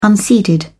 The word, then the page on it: unseeded: British English